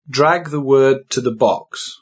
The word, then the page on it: drag: British English